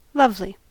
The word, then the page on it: lovely: American English